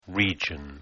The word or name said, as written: region